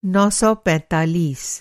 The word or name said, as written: नौ सौ पैंतालीस